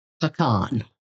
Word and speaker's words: pecan: Oh, by the way, how do you pronounce P-E-C-A-N? – I saw something the other day, and I'm going to pecan. – Pecan? – Pecan. – So it's P-U-H-C-A-H-N, pecan.